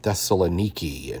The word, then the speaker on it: Thessaloniki: I still remember my pastor, he was a missionary evangelist, and talking about Thessaloniki, and that's how it's pronounced now, I guess, in riding the massive Ferris wheel with the missionary over there in Thessaloniki.